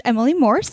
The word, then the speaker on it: Emily Morse: Do you ever get, so her name is Emily Morse.